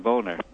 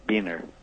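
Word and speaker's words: Boehner: No, I was talking about John Boner, Beener. Oh. I hear his name pronounced either way by either, but Stephanie Miller.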